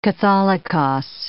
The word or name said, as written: catholicos